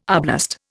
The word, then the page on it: arblast: Indian